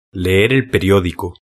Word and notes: leer el periodico: Spain Spanish

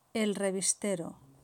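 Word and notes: el revistero: Spain

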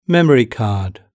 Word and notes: memory card: British English

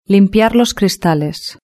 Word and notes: limpiar los cristales: Spain Spanish

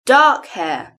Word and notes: dark hair: British English

